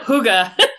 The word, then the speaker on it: hygge: Am I saying that right? – Yep, hygge.